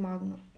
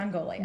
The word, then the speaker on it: Mongolia: Did you, you, guys travel a lot um, when you were missionaries in Mong? I can never say it right. Mongolia.